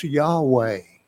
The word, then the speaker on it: Yahweh: And so what you have is YHWEH, pronounced Yahweh, Jehovah God, Yahweh.